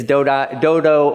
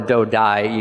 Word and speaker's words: Dodai: It's Dodo or Dodai, you can pronounce it that way too.